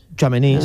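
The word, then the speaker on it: Tchouaméni: Chouaméni, sí. Chouaméni.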